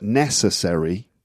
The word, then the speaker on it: necessary: So when you get a word like necessary, the stress is on the first syllable. – And necessary. – Not necessary. – Which is like accessory. – Sounds like accessory.